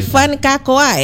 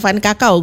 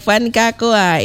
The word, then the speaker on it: Van Kakoa: Ini Van Kakoa ya? – Betul. – Bacanya kayak gitu ya? – Iya. – Oke, bukan Van Kakao bukan ya? – Bukan. – Oke, baik, Van Kakoa.